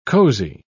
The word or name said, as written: cosy